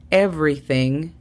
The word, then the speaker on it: everything: La pronunciación fonética sería J-A-O para how. Is es exactamente como se escucha. I-S. Everything. Aquí tenemos otra vez la pronunciación de la TH. Aprovecha y practica. Pones la lengua entre los dientes y soplas. Everything. Sería E-V-R-Y. Every. Y después añadimos thing. T-H-E-N-G. Everything. Going sería G-O-E-N.